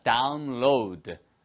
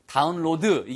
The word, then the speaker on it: download: 내려받게 하다, download. 아시겠죠? 그래서 all you have to do is download it. 발음만 주의하세요. 다운로드 이게 아니고 로우드예요. 로우드 발음이 로우드입니다.